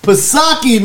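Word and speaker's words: Psaki: Pisaki, man.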